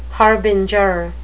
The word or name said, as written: harbinger